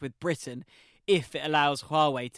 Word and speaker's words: Huawei: Well, actually, on the spying theme, how do you pronounce this? You know, Huawei. – Huawei. You could say Huawei. – Huawei. – Yeah, Huawei. I mean, there is a lot of problems, but I believe it's Huawei.